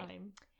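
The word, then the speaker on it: Haim: Heim.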